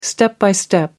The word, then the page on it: step by step: British English